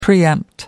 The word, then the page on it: preempt: British English